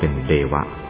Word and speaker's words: เทวะ: ทะออกเสียงเป็นดะเทวะก็ออกเสียงเป็นเดวะเป็นต้นถึงจะเขียนเป็นอักษรโรมันก็ใช้ตัวดี